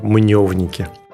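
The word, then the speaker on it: Мнёвники: Поэтому правильный вариант произношения именно Мневники.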